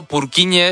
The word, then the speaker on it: Purkinje: Es que se escribe, en español sería Purkinje. – ¿Purkinje? – Pero Purkinje es en checo, que es porque es el apellido del descubridor.